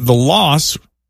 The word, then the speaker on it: Los: The way Jamie Lee says it, the loss or los rhymes with gross. – Los. – Los. – Los. – But Mariska pronounces it like it rhymes with boss.